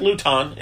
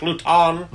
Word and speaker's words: Luton: Just because him and Jake Luton is what I'm going to start calling him. Jake Luton. – Luton. – It's spelled like Wonton.